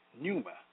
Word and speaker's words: pneuma: The word spirits in the Greek is pneuma, P-N-E-U-M-A, pneuma, pronounced pneuma, meaning a current of air or breath.